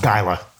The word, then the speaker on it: Gila: So like after we'd fight like 16 Gila monsters to get out there. – It's pronounced Gila. Gila monsters.